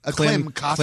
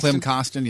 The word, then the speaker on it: Klim Kostin: If you're Jordan Kyrou, if you are Kim Kostin, isn't that Kostin? – Klim Kostin. Klim Kostin, yeah. Don't get his name wrong.